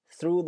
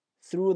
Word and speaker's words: through: 首先注意这个单词的发音，当你在一个单词里面看到 TH 两个字母的时候，有一个必备的发音原则，就是要咬住舌头，但是不要使劲咬啊，不要把咬的那个音都发不出来了，把舌头轻轻地搭在两个牙齿中间，让那个气从中间喷出来。 Through the forest, through the forest.